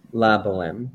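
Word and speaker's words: La Bohème: La Boheme, if anyone is interested in the original Rent.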